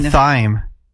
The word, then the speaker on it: thyme: What about thyme? – What about thyme? – That's how you spell. – But it's spelled weird.